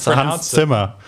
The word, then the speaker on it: Hans Zimmer: It's Hans Zimmer.